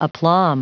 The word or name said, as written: aplomb